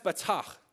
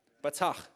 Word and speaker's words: batach: Right, the Hebrew word for trust, it's pronounced batach. You like that? Batach. Everybody say that?